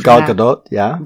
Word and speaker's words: Gal Gadot: Gal Gadot, yeah. – Gal Gadot, she is.